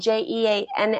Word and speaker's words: Jeanne: And uh, my mother name, and it's spelled J E A N N E. It's the French spelling. – Jean. Which we so if I'm in Europe teaching, it's Jean.